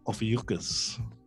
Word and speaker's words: Ophiuchus: So I'm sorry to be the bearer of bad tidings, but at least you weren't born under the star sign of Ophiuchus. Which, as we all know, rhymes with mucus.